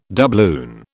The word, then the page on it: doubloon: British English